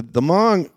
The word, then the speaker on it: Hmong: The Hmong, and that's spelled H-M-O-N-G, or an ethnic group that came from the area of China into Laos about 500 or so years ago.